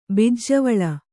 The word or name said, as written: ಬಿಜ್ಜೆವಳ